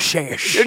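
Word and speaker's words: Šejić: I know I said it wrong, but... – You're close enough. – Sejic. – Think of it as if you're Sean Connery saying it. That's how I remember to do it correctly.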